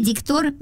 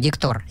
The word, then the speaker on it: диктор: Вот, кстати, хорошо вы сказали, что диктор. Здесь не указано ударение, но вы правильно произнесли, потому что вы уже знаете, вы, видимо, кастинг не первый раз проходите. Диктор, правильно.